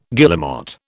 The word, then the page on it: guillemot: British English